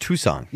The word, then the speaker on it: Tucson: C-A-N? – No, that's Tuscan. – No, it's C-O-N. – The city is pronounced Tucson.